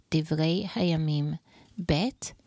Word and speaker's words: Divrei Hayamim Bet: In Hebrew, 2 Chronicles is said like this, Divrei Hayamim Bet, and it is today chapter 10.